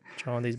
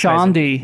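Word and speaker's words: Chaundee: Šondijs Brauns. – Tā izrunā viņu vārdi, ja? Nav kaut kāds Šondī. – Nav nejausmas. Šondijs.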